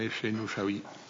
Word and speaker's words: Oussaoui: Die van D66, meneer Efsen Oussaoui. – Zeg ik het goed? – Ja, dank u, voorzitter.